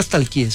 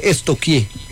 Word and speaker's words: stalkeé: Porque no se dice nunca stalkeé, se dice estoquié.